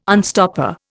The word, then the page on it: unstopper: Indian